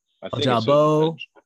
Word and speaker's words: Ojabo: And so is it Ojab? I don't want to say his name totally wrong. – Ojabo. – Okay. I think that sounds right.